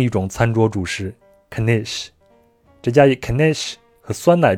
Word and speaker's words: knish: 嗯，它是 K A 啊 K N I S H 对，然后嗯。因为像英文里面有的时候你看到 K N 组合那个 K 好像都不发音嘛，像什么刀子就是 knife 那个 K 是不发音的。然后犹太人就他们一定会跟你说这个 K 要发音，它叫 knish。